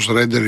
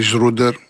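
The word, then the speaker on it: Schröder: Είναι αυτό ο Σρέντερ ή Σρούντερ, ανάλογα πώ τον προφέρουν.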